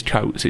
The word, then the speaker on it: Coates: I have a theory for why it's the name is originally Scottish, so it is his surname is Coates. It's just that the Uruguayans pronounce it wrong.